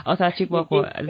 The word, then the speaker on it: Otachikwopo: I hope I'm pronouncing that. Otachikwopo. Did I get it? – Yes, yeah.